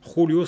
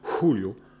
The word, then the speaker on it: Julio: Mais comme elle ne parvenait jamais à prononcer correctement la Jota, Julio se métamorphosait en Julio, avec un H puissamment soufflé du profond de la gorge,